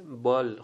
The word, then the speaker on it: bull: و یکی دیگه هم B-U-L-L، شبیه بال خونده میشه، بال، شبیه کلمه بوفالوی که ما میگیم اولش اونجوری خونده میشه.